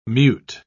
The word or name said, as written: mute